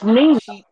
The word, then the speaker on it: Mina: She's at a a video shoot, and her friend Meta. I'm gonna be saying that wrong. Is there and it's Mina. – Mina. – Like Zena, Mina.